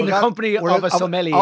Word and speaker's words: sommelier: I want to be a sommelier.